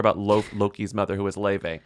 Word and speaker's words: Laufey: It's not Laufey. It's Laufey. – Which is okay. It's Icelandic.